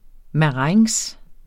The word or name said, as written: marengs